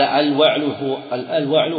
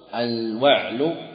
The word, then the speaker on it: الوعل: قال: الوعل هي الأروى. وفي القاموس: الوعل، الوعل بفتح الواو مع فتح العين وكسرها، الوعل وكسرها الوعل وسكونها الوعل، تيس الجبل، تيس الجبل.